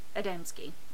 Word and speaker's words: Adamski: He's Polish. You used to speak Polish. How would you say it? – Adamski.